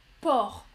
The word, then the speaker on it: porc: Alejandra, porc en français et on ne prononce pas le C.